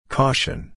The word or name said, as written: caution